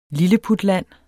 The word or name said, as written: lilleputland